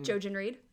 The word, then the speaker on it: Jojen: Jojen Reed.